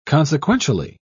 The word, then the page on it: consequentially: British English